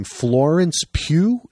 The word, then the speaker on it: Florence Pugh: Florence Pugh. Is that how you pronounce her name? – Yep.